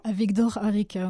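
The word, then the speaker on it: Avigdor Arikha: Donc, le parapluie rouge, c'est un tableau de d'Avigdor Arikna. Arica. – Avigdor Arica, oui, il n'est pas très facile à prononcer.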